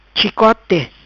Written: Chicote

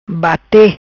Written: Bate

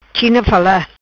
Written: China fala

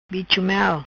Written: Bicho Mel

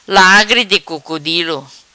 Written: Lagri Di Crocodilo

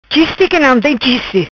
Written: Chiste qui nom tem chiste

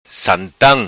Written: Santam

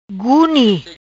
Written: Guni

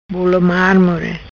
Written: Bolo Marmore

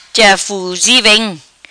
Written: Ja Fuzi Vem

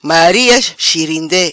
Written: Maria chirinde

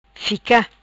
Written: Fica

